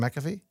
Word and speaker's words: McAfee: McAfee? – Nailed it.